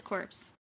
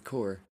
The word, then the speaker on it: Corps: It says corpse. – It's the Corps. – What do you mean? – It's pronounced Corps. – C-O-P? – Yeah. – S is pronounced Corps.